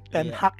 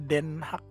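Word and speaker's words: ten Hag: Ten Haag ya bacanya. Gue pikir Den Haag bacanya.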